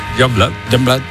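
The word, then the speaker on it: Yungblud: Es que era muy difícil de pronunciar, pero luego ¿cómo se pronuncia? – Youngblood.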